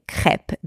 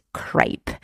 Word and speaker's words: crêpe: I often hear here in Australia, and I love my Australian people, by the way, but I often hear crêpe being pronounced crepe, which, you know, is fine. It is the way that they pronounce it here with the Australian accent, I guess. But yes, in the French version, the E is pronounced E and not A. Crêpe and not crepe.